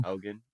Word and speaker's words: Haugen: Are we pronouncing it Haugen? Hogan? – Haugen, yeah.